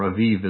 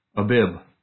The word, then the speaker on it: Abib: This one is the first fruits of the barley, the Abib, or Aviv as some people pronounce it. I hate that pronunciation. Abib.